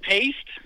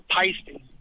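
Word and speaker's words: Paiste: But then, working with drummers, they would always say, oh no, it's pronounced pasty. – Pasty. All right, great. – Yeah. Okay. – All right, you answered my question. Thank you so much. – So it's not paste.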